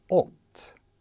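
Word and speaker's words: ott (את): Båda 2 uttalas ott.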